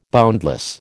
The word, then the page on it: boundless: American English